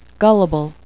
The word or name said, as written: gullible